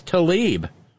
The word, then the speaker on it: Tlaib: Don't hate me, Joanne Ann Arbor says, but just an FYI that Rashida and her family pronounce their surname as Tlaib.